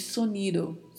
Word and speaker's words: sonido: E a letra O é sempre pronunciada mais fechada, sonido, e a letra D não é muito marcada, não falamos sonido, falamos soniro, soniro. Não se marca muito essa pronúncia da letra D.